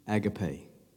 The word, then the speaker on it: agape: that God is love, that love is agape or agape. Some say agape, but the pronunciation of it is agape.